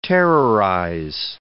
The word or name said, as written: terrorize